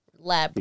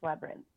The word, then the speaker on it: labyrinth: Labyrinth. – Because it has to specify it's Jim Henson Studios, I think. – I think it's Labyrinth. Like, I say it in two syllables, you say it in three.